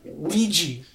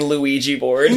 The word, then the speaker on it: Ouija: And it looks like this is a review for the Ouija board. – Ouija board. – Yeah, the Ouija board. – Ouija? – The squeegee board? – Ouija. – I was just hearing Luigi board.